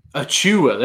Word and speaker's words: Achiuwa: With Precious for Miami, Precious Akuya? – Oh, Precious Achiuwa? – Achiuwa, there we go.